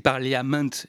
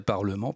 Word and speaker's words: Parliament: Parliament, ça s'écrit Parliament et longtemps j'ai prononcé ça Parliament avant de savoir que non, tout anglais écrivait Parliament mais ça se prononçait Parlement, Parliament. Quoi.